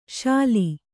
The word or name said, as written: ಸೂಲೆ